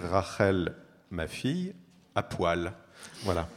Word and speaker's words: Rachel: Et en hébreu, on dit Rachel. Enfin, Laban dit Rachel Biti Aktana, ce qui en prononciation ashkénaze se dit Rochel Biti ou Bissi, je ne sais pas, Haktone.